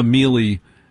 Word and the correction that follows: Millie: How do you pronounce her first name? Miley or Mill? No, I'm a Millie Alcock girl.